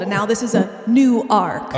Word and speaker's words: Newark: Two syllables, not like the one in New Jersey. – Okay. Because the one in New Jersey is Newark, and it's kind of said in one syllable, but you guys have Newark, like Noah from the Bible. It's his second ark, like he blew up the first one.